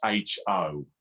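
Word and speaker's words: ho (ὁ): Now, just imagine the word in English, H-O, but in Greek, it's an O with a little comma on the top of it, all right? And when you see that, that's pronounced ho, and it's a definite article, the.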